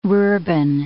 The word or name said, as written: rurban